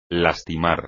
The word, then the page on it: lastimar: espagnol